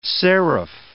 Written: serif

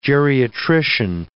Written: geriatrician

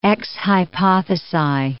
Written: ex hypothesi

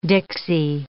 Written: dixie

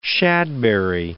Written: shadberry

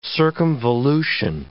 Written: circumvolution

